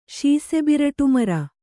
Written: ಶೀಸೆಬಿರಟುಮರ

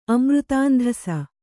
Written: ಅಮೃತಾಂಧಸ

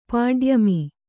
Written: ಪಾಡ್ಯಮಿ

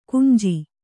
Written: ಗಂಜು

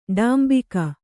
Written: ಡಾಂಬಿಕ

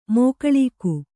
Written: ಮೋಕಳೀಕ

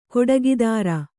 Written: ಖಡುಗಧಾರೆ

